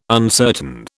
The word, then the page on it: uncertain: British English